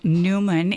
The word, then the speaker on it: Neumann: which is the Feast of St. John Neumann. And I'm understanding that it's actually pronounced Neumann. Is that right? – Yes.